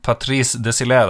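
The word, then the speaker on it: Désilets: Patrice Desilé, de uttaler det i Frankrike. Patrice Desilé, faktisk.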